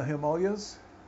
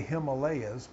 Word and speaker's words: Himalaya: It used to be the yogi could go someplace and out in the Himalayas. In the West, they say Himalayas, but it's actually pronounced Himalaya. You know, Heem means ice and snow.